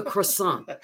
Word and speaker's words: croissant: And the waiter said, ah, croissant, not croissant, croissant.